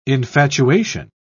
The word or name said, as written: infatuation